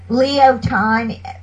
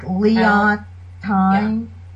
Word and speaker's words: Leontine: Leo Time, like Leon Time. – L-E-O-N-T-I-N-E.